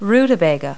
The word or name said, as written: rutabaga